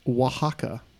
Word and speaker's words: Oaxaca: Correctly spell the name of the Mexican state pronounced Oaxaca. – That's going to be fun. Oaxaca.